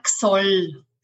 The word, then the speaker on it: Xol: It's Simona Xol. – Simona, Xol, like an X.